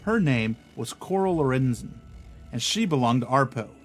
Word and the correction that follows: APRO: APRO. I always say it wrong. APRO, the Aerial Phenomenon Research Organization.